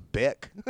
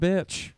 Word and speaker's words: Bick: And by the way, I was pronouncing that wrong until they specifically pointed out it's Bick. – So yeah. It only mentions like it like twice before. It's like, it's not bitch. Don't say this wrong.